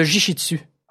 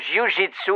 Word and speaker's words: jiu-jitsu: Attends, moi, le monde m'approche pas parce qu'ils savent que je suis très bon dans le jiu-jitsu. – On dit le jiu-jitsu. – C'est ça, je veux dire. – La technique de combat japonaise. – Je le sais, je l'ai mal prononcé.